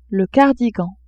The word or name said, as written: cardigan